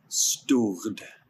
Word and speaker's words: Stord: Stord. – Stored? – So, yeah, so they roll their R's, so like translated to English, it's stored. – Stored. – Yeah, we pronounce stored.